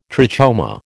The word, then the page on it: trachoma: American English